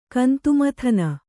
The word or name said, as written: ಗಂಧಮಾದನ